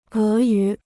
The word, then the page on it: 俄语: é yǔ